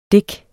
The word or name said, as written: dik